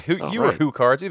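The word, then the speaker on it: HuCards: I received the TG-16 CD attachment a few Christmases later and accumulated 3 CD games and 12 or 13 Hue cards total. Did I pronounce that right? – Yes. – Hue cards? – Well, Hue cards.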